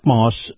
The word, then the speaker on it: Maus: Now, Moss is spelled M-A-U-S.